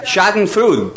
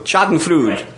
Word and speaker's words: Schadenfreude: There's a German word, I think it's pronounced Schadenfreude. Schadenfreude. Schadenfreude.